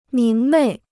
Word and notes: 明媚: míng mèi

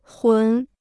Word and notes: 魂: hún

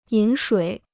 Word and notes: 饮水: yǐn shuǐ

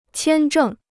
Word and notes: 签证: qiān zhèng

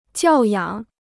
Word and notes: 教养: jiào yǎng

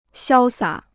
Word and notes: 潇洒: xiāo sǎ

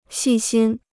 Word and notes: 细心: xì xīn

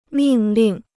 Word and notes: 命令: mìng lìng

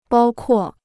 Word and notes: 包括: bāo kuò

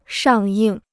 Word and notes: 上映: shàng yìng

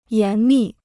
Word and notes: 严密: yán mì

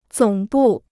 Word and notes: 总部: zǒng bù

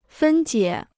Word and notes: 分解: fēn jiě

